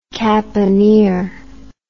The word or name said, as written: caponier